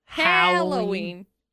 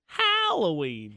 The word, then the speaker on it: Halloween: Like Albert, Hal, Halloween. – Halloween. – Hey, howdy. Halloween. – So pick one of those. Those are all acceptable for how you pronounce Happy Halloween.